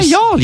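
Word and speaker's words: Youghal: Yeah, Yall, yeah. – It looks like Yugle. – Yeah, it does. – It's spelled Yugle like Dougal, but it's Yall. – Spelled Yugle, pronounced Yall, ready for this.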